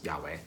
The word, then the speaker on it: Yahweh: Whenever it says Lord, all caps, in the Old Testament, it's actually standing in for the Hebrew name of God, which we pronounce Yahweh.